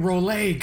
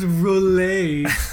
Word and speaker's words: Rolex: It's pronounced fucking Rolay, guys. Yeah, these are pretty expensive. It's pronounced Rolay and Omega.